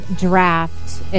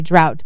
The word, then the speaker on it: draught: So here, D-R-A-U-G-H-T would be drought, but there it's pronounced draft.